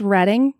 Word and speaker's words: Reading: And it's Reading, not Reading. And it's the Schuylkill River, not any other weird pronunciation that you might have.